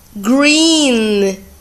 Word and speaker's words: green: Os exemplos são green, green é verde, G-R-E-E-N, green, ok? Tenho dois E's, quando eu tenho dois E's, eu pronuncio como I, green.